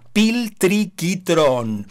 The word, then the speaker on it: Piltriquitrón: Si alguien quiere buscar en Google, se escribe tal como suena, Piltriquitrón.